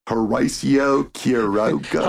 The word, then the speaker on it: Horacio Quiroga: That's how you really pronounce it, folks, if you're not a stupid white redneck like me. Horacio Quiroga.